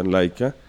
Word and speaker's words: Leica: que se escribe Leica y se pronuncia Leica.